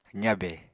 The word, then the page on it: zabe: apatani